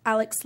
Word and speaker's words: Alix: and Snapchat at X Alex Lavelle X, and my name is spelled with an I, so it's A-L-I-X Lavelle.